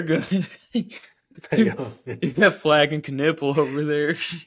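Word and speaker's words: Knueppel: I think it's Knipple. I think that's how he said it one day.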